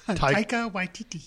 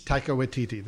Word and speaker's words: Taika Waititi: You say the director's name, because I'll butcher it. – Taika Waititi. – Taika Waititi. There you go.